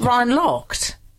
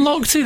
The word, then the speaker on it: Lochte: Ryan Locked. – It's Ryan Lochte. That's how they pronounce it.